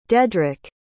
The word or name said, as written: dedric